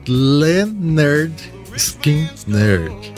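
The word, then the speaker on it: Lynyrd Skynyrd: Pronounced Leonard Skinner. Isso mesmo, eles escreveram como se pronuncia corretamente o nome da banda, já que por causa da adição do Y, o nome era pronunciado pelos DJs como Leonard Skinner, né?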